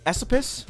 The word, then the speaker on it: Esopus: Yes, I said Esopus. If you look it up, there's some controversy on how to say the word Esopus. So the people who live in that area, the area that is now inside of Ulster County, they usually say Esopus, I believe.